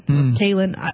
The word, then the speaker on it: Kaylin: I didn't want to accept it, but she made me take it, Kylan said. Kaylin. Her name's spelled weird. That's why I keep pronouncing it.